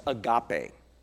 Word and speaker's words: agape: And the one that is repeatedly used for Christian behavior is the one that's pronounced agape.